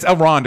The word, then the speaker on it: Elrond: Okay, so then what's the Eldron stuff? – Elrond is just helping this. – Oh, how do you say his name? – Elrond. – I just call him Hugo Weaving every time. – It's Elrond.